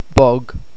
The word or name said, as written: bog